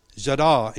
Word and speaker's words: yadah: Yadah in Hebrew, oh, give thanks. And it's said yadah, but it's spelled yada, like yada, yada, yada, you know?